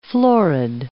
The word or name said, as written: florid